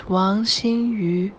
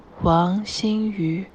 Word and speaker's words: Wang Xinyu: Let's listen to her official WTA pronunciation file. Here it is. – My name is Wang Xinyu. Wang Xinyu. – Oh boy, I need to listen to that again, because that's not how I was saying it.